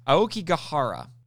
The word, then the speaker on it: Aokigahara: The subtitles call it Aoki Forest, but the proper way to say it, and next time I watch the movie, I'm going to pay attention to see if this is actually how they say it in the Japanese, but it's Aokigahara.